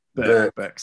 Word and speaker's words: Bourke: Bourke, sorry. Yeah. in 1975. Remarkably, this is where he met his wife-to-be, Roseanne, who had come to Australia from Illinois in the USA. Roseanne was also teaching in Bourke, and Rod has now been married to Roseanne for nearly 45 years.